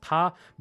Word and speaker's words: 他: In Mandarin, in Chinese, when we say him or her, it's pronounced ta, but it's only a slight difference in the way the characters are written that changes this meaning.